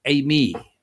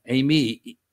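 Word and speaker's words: eimi (εἰμί): In the Greek, that's ego, eimi. E-G-O, ego, meaning I, eimi, meaning I am. I am, in other words, the emphasis. Eimi, E-I-M-I, E-I-M-I, transliterated from the Greek, E-I-M-I, and it's pronounced ego, eimi.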